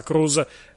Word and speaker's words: Cruz: fa Cruz, scritto C-R-U-Z, però la pronuncia è assolutamente la stessa, Cruz, Cruz,